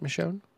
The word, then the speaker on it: Michonne: Did I say that right, Michonne? – Yep.